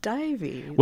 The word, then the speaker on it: Dives: Well, Dives is absolutely the British Latin pronunciation, but the Latin pronunciation, for whatever that's worth, I don't know. Those guys probably didn't know what they were saying, is supposedly Divus, I think.